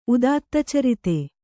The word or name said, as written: ಉದಾತ್ತಚರಿತ